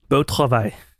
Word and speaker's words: Beau travail: Yeah, and we had a nice little exchange via text, and then you said, "Hey, how would you pronounce this in real French? – Beau travail.